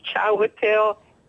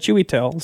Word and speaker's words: Chiwetel: Well, no, when he gets the Oscar, and his name is Chiwetel Ejiofor. I met him before, and he told me that people call him Chewy. – Because that's how it's spelled almost, C-H-I-W-E-T-E-L, Chewy. I would have said Chiwetel, so.